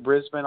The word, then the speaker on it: Brisbane: Because Brisbane, is it Brisbane? Is that how you pronounce it? Brisbane, Australia? – That's very well done. You did perfect. Yeah, that is how it's pronounced.